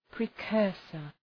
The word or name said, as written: precursor